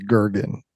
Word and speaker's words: Goergen: I'm pretty mind-bottled right now, where your thoughts get trapped in a bottle about that Scotty's name is pronounced Gergen, even though it's spelled G-O-E-R-G-E-N.